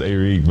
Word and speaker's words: Arik: It's pronounced Arik, but I usually say Eric because everybody else says that.